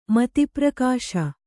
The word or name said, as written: ಮಧುಪರ್ಕ